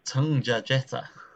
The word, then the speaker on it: Tungjatjeta: Tungja Jeta.